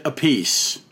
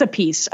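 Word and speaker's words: Appice: Is it Carmine, is it Alpice or is it Alpice? – He pronounces it Apice. – It is a piece.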